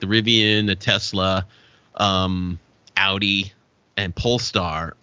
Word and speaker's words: Rivian: And behind Rivian is the number one. I think that's it. That's how you pronounce that car company, right? – It is. Rivian.